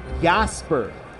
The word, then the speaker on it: Yosber: So we've got Jasper, according to the West Michigan pronunciation guide, Jasper. Y-O-S-B-E-R. Jasper Sanchez on one side. Jasper hailing from Maracay, Venezuela. And Yeison. Y-E-H-I-Z-O-N out of La Romana, Dominican Republic.